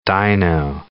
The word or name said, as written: dino